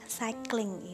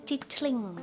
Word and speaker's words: cycling: Cycling itu adalah bersepeda. Hati-hati ya, ini tulisannya cicling, tapi bacanya cycling.